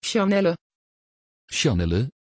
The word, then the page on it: Shanelle: Dutch